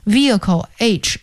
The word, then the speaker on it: vehicle: Vehicle 这个词请大家注意一下，它的拼写是 V-E-H-I-C-L-E， Vehicle H 是不发音的，就是机动车的意思。